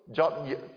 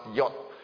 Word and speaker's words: jot: That means that when Christ says that he fulfilled the law, okay, and not one jot or tittle, jot, actually it's pronounced yot, jot or tittle is the particles in the Hebrew script.